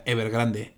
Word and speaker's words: Evergrande: No sé cómo se llama, no sé cómo se pronuncia, lo estuve buscando y todos lados sea Evergrande.